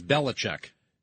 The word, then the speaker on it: Belichick: It is Belichick.